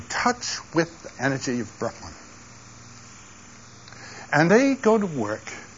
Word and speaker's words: Brahman: The Brahmins are the priests, and they are related to Brahman, B-R-A-H-M-A-N, without a long A.